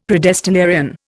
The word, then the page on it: predestinarian: Indian